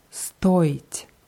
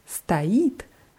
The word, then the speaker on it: стоит: Und bei stoit ist es wichtig, die Betonung ganz deutlich auf O zu machen, weil stait heißt was anderes. Stait heißt steht.